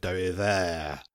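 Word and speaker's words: dérive: Sorry, French listeners. I can't pronounce French. I can say it with a French accent if you like. Derivé. Does that sound convincing?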